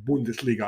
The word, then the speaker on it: Bundesliga: Okay, let's move south and look at the Bundesliga and Bayern Munich. I should say Bundesliga. I don't want to apologize to my German friends for that mispronunciation. Let's say Bundesliga and Bayern Munich at 45 points leading.